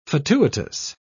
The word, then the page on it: fatuitous: British English